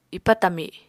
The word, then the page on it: ipa tami: apatani